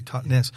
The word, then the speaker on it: Totnes: Yeah, it's Totnes, as you say it properly, Totnes.